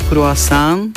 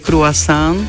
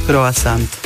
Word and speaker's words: croissant: Tá pôvodná výslovnosť sa odporúča croissant, ale tým, že je to v slovenskom prostredí a nie všetci ovládajú francúzštinu, tá francúzska je croissant, tak sa pripúšťa v slovenčine aj výslovnosť croissant. Aj s T, aj O.